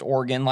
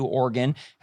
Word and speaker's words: Oregon: I also noticed that you being from the Midwest, you pronounced Oregon like I do, Oregon. Have you ever heard anyone pronounce that state any different? – Maybe just more, you know, just putting the emphasis on a different syllable, you know. – Oregon is the one I hear from East Coasters all the time. I think that's it. I think it's the East Coast thing, but wow.